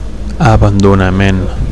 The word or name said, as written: abandonament